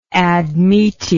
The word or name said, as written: admete